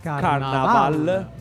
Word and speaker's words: Carnaval: Attenzione, non con la I ma con la A. – Carnaval. L'accento sulla ultima A.